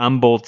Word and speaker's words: Humboldt: There is also Cam Dewberry out of Humboldt, Texas. Yes, I know you leave out the H there, so anyone who's from Texas, you're welcome.